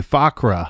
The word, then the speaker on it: Fuqra: Fakwa, And I'm not saying that right. F-U-Q-R-A, Fakra.